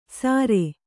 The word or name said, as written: ಸಾರೆ